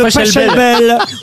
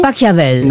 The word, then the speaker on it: Pachelbel: Oui, parce que c'est le canon de Pacavel et tout le monde le connaît. – Mais non, c'est de Pachelbel.